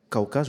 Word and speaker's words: Cauca: Cauca, je prononce bien, c'est juste ça.